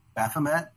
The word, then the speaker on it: Baphomet: Baphomet. How do you say it? – Baphomet.